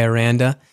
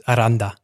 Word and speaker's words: Aranda: I go by Aranda, and it depends. If I'm talking to my grandma, then it's Aranda.